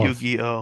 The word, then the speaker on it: Yu-Gi-Oh: Yu-Gi-Oh? I know. – Yu-Gi-Oh.